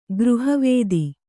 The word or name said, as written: ಗೃಹವೇದಿ